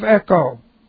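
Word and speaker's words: echo (ἔχω): Okay, this time, We have, if you have, we said it if, third class condition, if you have, present active subjunctive of echo. E-C-H, long O, it means to have something, to have, to possess.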